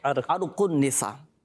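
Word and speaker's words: عرق النساء: دوسرا ارق النساء, اچھا لوگوں میں مشہور ہے ارق النساء لیکن صحیح تلفظ کیا ہے؟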